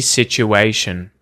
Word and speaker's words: situation: S-I-T-U-A-T-I-O-N. Situation. Do you hear a hard T at all in this word? Do either of these T's get pronounced as a hard T, like a T, T sound, as in the word sticky, right? Sticky. Situ, Situation. Situation. No, there's something going on called assimilation here, where the U sound after the first T combines with the T to get this ch, situ, situ, the ch sound.